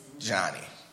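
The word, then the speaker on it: Johnnie: My name is spelled J-O-H-N-N-I-E. E. Johnny. Not John. Not Jonathan. Not J-O-N. Not J-O-H-N.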